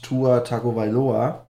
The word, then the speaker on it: Tua Tagovailoa: Und da hat Tua Tagovailoa, so spricht man das aus, äh, bekannt gegeben, dass er am 2020er NFL-Draft teilnehmen wird.